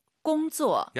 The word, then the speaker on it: 工作: 일하다 꽁쪼 쌍지읒하지 마시고 工作。 – 약간 지은 느낌으로 힘 빼고 발음하시는 게 포인트입니다. 같이 한번 따라해 볼게요.